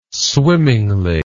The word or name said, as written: swimmingly